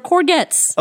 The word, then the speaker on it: courgette: It's pronounced courgette. – Courgette? – I put a little bit of courgette in my profiteroles. – Courgette. – That's how they say it on Great British Bake Off.